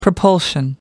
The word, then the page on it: propulsion: American English